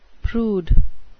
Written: prude

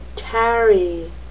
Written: tarry

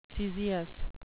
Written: caesious

